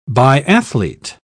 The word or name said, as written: biathlete